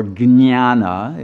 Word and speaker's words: jnana: And the word jnana, or jnana, it actually is a G-N-Y-A-N-A, if you pronounce it accurately.